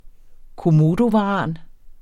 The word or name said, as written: komodovaran